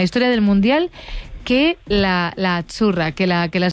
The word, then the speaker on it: Azzurra: Sí, como porque pizza, la Z no es la Z como decimos en catalán tan sonora, no. – Ah, es azzurra. – Muy bonito, muy bonito. – Es como pizza.